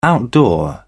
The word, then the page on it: outdoor: British English